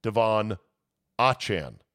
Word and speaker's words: Devon Achane: He told the Miami Dolphins to make sure everyone knows that he wants his now name pronounced Devon Achane, not Achane.